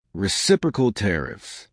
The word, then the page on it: reciprocal tariffs: American English